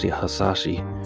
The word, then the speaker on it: Hasashi: So one thing I want to say is I mispronounced one of the characters of Hiroyuki Sanada, his character Hanzo Sasashi. I said something else, but I want to correct it. So I know some Japanese listeners out there, to pronounce it right. So Hanzo Hasashi is the correct name for his character that he played for the Scorpion.